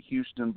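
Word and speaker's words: Huston: Sure, and the thing is that people need, first thing people need to understand is I spell my last name Houston. I do not spell it like the city. I pronounce it Houston, but it's not spelled like the city.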